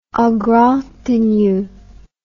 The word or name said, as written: en grande tenue